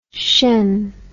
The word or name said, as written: tion